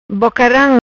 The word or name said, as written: Bocaram